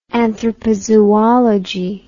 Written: anthropozoology

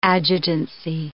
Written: adjutancy

